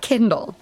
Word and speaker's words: Kindal: Note, Kindle is pronounced like Kindle, and she is always holding an Amazon Kindle.